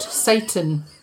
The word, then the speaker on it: Sayton: This morning I delivered little baby S-A-Y-T-O-N, pronounced Satan, as in King of the Underworld.